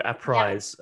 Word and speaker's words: APRISE: Yeah, APRISE, like apprising you of.